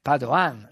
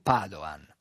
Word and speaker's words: Padoan: l'ottimo Piercarlo Padoan. Matteo ha sbagliato l'accento del cognome, Padoan, non Padoan.